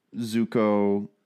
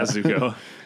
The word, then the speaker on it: Zuko: There was just a lot that I really liked, and I liked the episodes where Zuko is. Did I say that right? – Yeah. – I'm like so tired. – Yeah, Zuko.